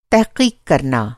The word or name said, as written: तहक़ीक़